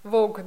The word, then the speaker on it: Vogue: Эмма в журнале Teen, у меня плохо с английским, у меня маленький мозг, как это читается? Teen Vogue. – Vogue, да.